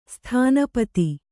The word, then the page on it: ಸ್ಥಾನಪತಿ: Kannada